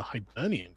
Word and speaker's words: Hibernian: The Hibernian question? – I feel like I said this wrong, too. – No, you nailed it.